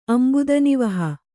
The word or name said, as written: ಅಂಬುದನಿವಹ